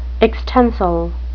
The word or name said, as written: extensile